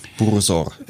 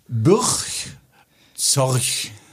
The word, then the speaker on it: Burgzorg: Burg Zorg, also B-U-R-G. Z-O-R-G, Burgzorg. Wie heißt er wohl richtig? Wie wird er wohl ausgesprochen? Bursor. Nein, es ist äh, übrigens ein Niederländer, vielleicht was? hilft das was. Ah, Bursor. Nee, ganz krass. Soll ich es dir sagen? Ja, bitte. Ja. Burchzorch. Bürch, Du kannst es dir merken fürs nächste Mal, weil das, das Rückspiel kommt ja auch noch.